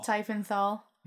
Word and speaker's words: Tiefenthal: I'd say the difference between Clank and Taverns of Tiefenthal. Tiefenthal.